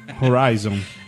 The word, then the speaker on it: Horizon: Horizon. – Horizon. – É, mas tem que ser com essa pronúncia.